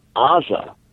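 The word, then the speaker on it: Aza (עזה): In Hebrew, it's spelled with an ayin, Aza.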